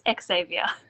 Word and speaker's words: Xavier: You keep dropping the X on my name. It's Xavier.